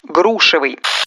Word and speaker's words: грушевый: Грушевый. Закрепим плодово-ягодную тему. Если говорить о чем-то, сделанном из груши, ставьте ударение в прилагательном на первый слог.